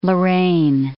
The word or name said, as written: Lorraine